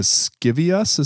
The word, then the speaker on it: Scivias: this is called the Scivias. Is that how mm-hmm. you pronounce that? – Oh, yes, yes.